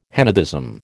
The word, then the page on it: henotheism: American English